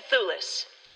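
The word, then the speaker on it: Thewlis: I can never get his name right either. – Synecdoche. – Thulis. It's David Thulis.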